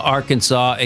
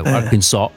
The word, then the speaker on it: Arkansas: Arkansas, e quindi loro non capaci, scusa, non volendo pronunciare la S, alla fine loro dice Arkansas.